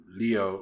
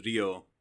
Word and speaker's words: Ryo: I just pronounce it with the Japanese pronunciations Ryo.